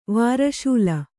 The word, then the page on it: ವೃಷಲಿ: Kannada